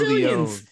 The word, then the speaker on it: Leon: We got KC Squared, Toxic Attraction, Leona and Faraz. – What did you just say? Leona and Faraz? Yulisa Leon and Valentina Faraz, man. We got to get the name right. You said Leona.